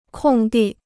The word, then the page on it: 空地: kòng dì